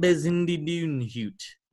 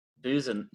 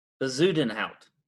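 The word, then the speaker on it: Bezuidenhout: I don't think you said that right. – Yeah, maybe I didn't. – I think it's Buzin. Let me try to say this because I'm always bad at saying Bezudenhout.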